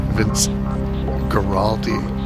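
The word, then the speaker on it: Vince Guaraldi: Vince Giraldo. Sorry about that. Vince Giraldi did Choro with Bolasetti.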